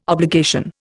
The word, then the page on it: obligation: Indian